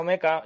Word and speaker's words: mecha: Eh, mecha o meca, yo voy a utilizar mecha. Mecha es la, eh, palabra para utilizar, generalmente está aceptado como para utilizar eh, robots gigantes, gigantes pilotados.